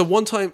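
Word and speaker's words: 1TYM: The number one, T-Y-M, all caps. – So One Time, well, the reason I know it's pronounced One Time is because I listened to one of their tracks, which is called One Time.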